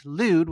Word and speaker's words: Lwyd: And lewd, which is spelled L-W-Y-D, translates to white.